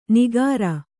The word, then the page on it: ನಿಗಾರ: nigāra